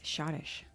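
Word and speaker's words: Schottische: The C-H. The C is silent. It's Shottish.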